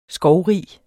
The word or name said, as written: skovrig